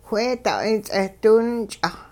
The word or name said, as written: wheeda'ay ts'eh diwinch'ah